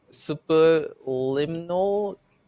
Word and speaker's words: Superliminal: We have Flynn, Sonic Crimson, I Am Fish, which Rock is talking about here, Skateboard 2, Superliminal, I don't know if I'm saying that right.